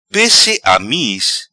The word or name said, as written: Pesse Amiz